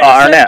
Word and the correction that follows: Garnett: or, Garnett.